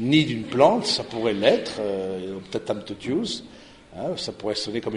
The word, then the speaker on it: Optatam totius: Et donc, Optatam totius, c'est comme ça ça se prononce, le latin c'est très facile de ce point de vue-là, Optatam totius, hein, et donc du coup, et c'est au numéro 11.